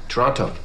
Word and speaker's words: Toronto: Toronto. – Toronto. Canadians don't pronounce the T.